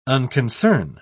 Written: unconcern

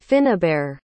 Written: Finnobarr

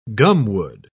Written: gumwood

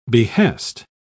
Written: behest

